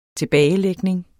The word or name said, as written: tilbagelægning